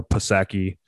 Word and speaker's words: Psaki: Well, it's P-S-A-K-I, right? But right. Yeah, some people make mock her and call her Psaki. I think it's pronounced Psaki, but the P is silent.